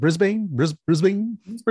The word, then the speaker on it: Brisbane: Brisbane. – Yeah. – Yeah. If I can't murder their names, I'll murder their city, you know? – That's right. – So anyways, they're a three-piece band originating from Brisbane.